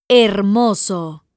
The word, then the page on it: hermoso: Spanish